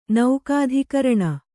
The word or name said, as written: ನೌಕಾಧಿಕರಣ